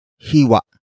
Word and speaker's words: hiwa: It comes from the root word hiwa. Hiwa. Hiwa. Hiwa is spelled as H-I-W-A. Note the glottal stop at the end.